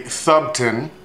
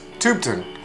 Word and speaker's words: Thubten: It's phonetically Thubten, but he's got a, as he explains it, the TH is like Thomas, and the U is like OO, so it's Thubten Comerford of Buckman Brines.